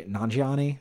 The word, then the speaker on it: Nanjiani: When I always think, I've mentioned this once before on here, and I don't remember what show, but Kumail Nanjiani, is that how you pronounce his last name? – Yeah, Kumail.